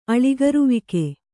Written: ಅಳಿಗರುವಿಕೆ

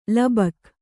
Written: ಲೋಭಕ

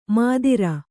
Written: ಮದಿರ

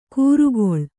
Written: ಕೂರುಗೊಳ್

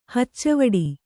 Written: ಹಚ್ಚವಡ

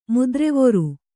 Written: ಮುದ್ರೆವೊರು

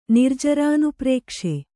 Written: ನಿರ್ಜರಾನುಪ್ರೇಕ್ಷೆ

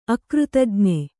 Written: ಅಕೃತಜ್ಞ

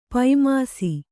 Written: ಪೈಮಾಸಿ

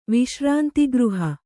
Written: ವಿಶ್ರಾಂತಿಗೃಹ

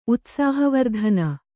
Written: ಉತ್ಸಾಹವರ್ಧನ

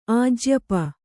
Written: ಆಜ್ಯಪ

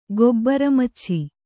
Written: ಗೊಬ್ಬರಮಚ್ಛಿ

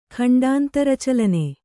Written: ಖಂಡಾಂತರಚಲನೆ

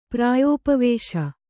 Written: ಪ್ರಾಯೋಪವೇಶ

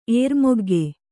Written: ಏರ್ಮೊಗ್ಗೆ

